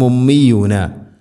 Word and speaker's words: ഉമ്മിയൂന: ഓതുന്ന സമയങ്ങളിൽ വമിൻഹും ഉമ്മിയൂന, വമിൻഹും ഉമ്മിയൂന ഇങ്ങനെ വരുന്നത് ശ്രദ്ധിക്കണം. ഹംസ ക്ലിയർ ആയിട്ട് ഉച്ചരിക്കണം. വമിൻഹും ഉമ്മിയൂന, വമിൻഹും ഉമ്മിയൂന. മീമിന് ശദ്ദുണ്ട്, മണിക്കണം. ഉമ്മിയൂന.